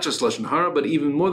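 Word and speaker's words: lashon hara (לשון הרע): the Lashon Hara, Afilu El Emes.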